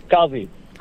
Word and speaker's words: Kazi: Kazi. – Can you spell that for me? – K-A-Z-I. – K-A-Z-I.